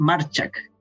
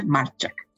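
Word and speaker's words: Majchrzak: Y es Marchak. Marchak. Porque le he escuchado los juegos a los jueces de silla y dice Marchak.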